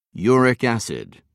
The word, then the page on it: uric acid: British English